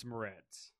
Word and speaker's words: Moretz: How do you say her last name? Ritz. – Grace Moretz. – Oh, I, I said I said it right.